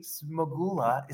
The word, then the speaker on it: Smagula: Jimmy Smagula is here.